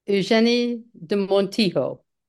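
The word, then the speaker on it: Eugénie de Montijo: Eugène de Monito. – Let's try it at Eugenie de Montijo. – There you go. See, butchered it.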